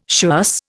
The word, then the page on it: schuss: Indian